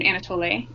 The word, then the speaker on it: Anatole: Um, she does end up marrying a Congolese man um, named Anatole. Is how I know to say his name. Is there a correction for that? Does anybody know better? – Sounds good. That, okay. That, that's how I was saying it in my head.